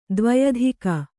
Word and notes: ದ್ವ್ಯಧಿಕ: dvyadhika

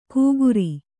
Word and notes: ಕೂಗುರಿ: kūguri

